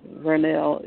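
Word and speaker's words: Vernelle: My name is Vernell. It's spelled with a V, like in Virginia. It's a V-E-R-N as in no, E-L-L-E.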